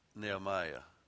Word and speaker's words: Nehemiah: We say Nehemiah, as in Nehemiah, and it actually should be pronounced Nehemiah. Like, kind of sound like an Australian when you say that. Can y'all say that? Nehemiah. Because the E's not really long there, but Nehemiah.